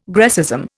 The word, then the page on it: Grecism: Indian